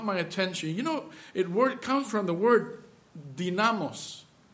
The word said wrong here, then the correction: dunamis: I'm sorry, dunamis. This is the way you pronounce it.